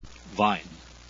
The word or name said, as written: wein